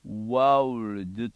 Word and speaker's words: world: world はね、 world と発音しますね。 sin of the world、最後がルドにならなきゃいけませんね。